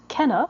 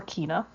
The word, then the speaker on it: Kena: And it is Kena, or Kena, depending on how you want to pronounce it. Kena, Bridge of Spirits. So that's K-E-N-A, so you can take whatever...